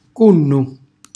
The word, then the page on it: cunnu: calabrese